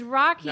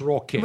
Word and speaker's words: Roque: It's Rocky, nah. But it's spelled... – You could probably run for mayor. – Rocky swing the vote. – Spelled R-O-Q-U-E. – It's Rocky.